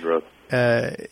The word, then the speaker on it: Rohde: But he Rohde, I think, is the way you pronounce his name. – Yeah, David Rohde.